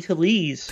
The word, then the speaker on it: Tellez: How do you pronounce his last name? Tellez. – Rowdy Tellez.